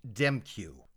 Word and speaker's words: Demkiw: So we find out that this car belongs to Dwayne Demkiw. Wild pronunciation on that last name. The last three letters are K I W, and you pronounce it Q.